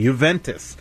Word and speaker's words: Juventus: That's how we pronounce it, Tony. Juventus.